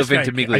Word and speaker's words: Ventimiglia: Milo Ventimiglia. – I just call him Jess. I don't even call him by his real name. I just call him Jess. – Yeah. Milo Ventimiglia.